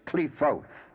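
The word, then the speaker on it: Qliphoth: But as I explained before, the P is a pay, and pay has a dual pronunciation depending upon where the dot or the doggish is placed. The preferable pronunciation is Klefoth. There is a set of Klefoth referred to every Sephira.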